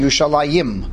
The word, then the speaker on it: Yerushalayim: And then what we do is that we put an A sound towards the end, Yerushalayim.